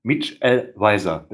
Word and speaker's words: Mitchell Weiser: Ich habe den Namen falsch ausgesprochen. Mitch L. Weiser, bitte.